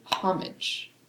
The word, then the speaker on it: homage: Homage. – Oh, that's interesting. – But Americans, I guess, say homage is the American pronunciation. – Homage. – So they pronounce the H in that instance.